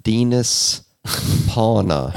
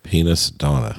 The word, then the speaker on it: Dinas Pauna: And just to say, to go with the pronunciation of his name, it looks like Dinas Pauna. If you're looking for the spelling to figure out how you spell his name, that's kind of, if phonetically, I just told you his name. – Penis Donna. – No. – No. Oh, backwards. Sorry. – I love the helmet. No. Dinas Pauna, like penis sauna.